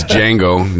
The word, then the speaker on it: Django: First of all, it's Django, no D, idiot. – The D is silent, motherfucker.